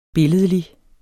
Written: billedlig

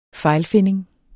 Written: fejlfinding

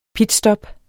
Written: pitstop

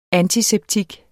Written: antiseptik

